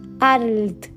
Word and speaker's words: Arlt: Entonces, satisfecho de ponerlo en un apuro al pedagogo, le dictaba: Arlt cargando la voz en la L.